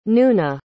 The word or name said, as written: noona